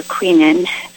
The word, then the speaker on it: quinine: it says it contains hand-picked quinine, or quinine, some people pronounce it, and it's called Q-Tonic, and it comes in a big bottle, and it's quite good.